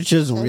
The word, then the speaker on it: Chisholm: That's how you say it? Shirley Chisholm. Yeah.